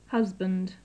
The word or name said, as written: husband